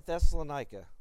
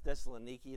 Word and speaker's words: Thessaloniki: Paul in Thessalonica. It's actually pronounced Thessaloniki. That's how they pronounce it. But old habits die hard, so let's just go with Thessalonica.